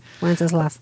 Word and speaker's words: Wenceslas: Good King Wenceslas. Thank you. I don't know why I said that wrong. Said that wrong for 40 years. I'll never say it right. – Wenceslas.